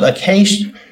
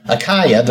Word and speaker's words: Achaia: Greet my beloved Epaenetus, who is the first fruits of Acacia, Achaia, Achaia, Achaia. There's several pronunciations for that too. It's Asia. It's basically Asia.